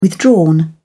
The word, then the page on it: withdrawn: British English